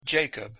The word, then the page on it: jacob: English